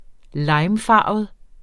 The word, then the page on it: limefarvet: ˈlɑjmˌfɑˀvəð; ˈlɑjmˌfɑːvəð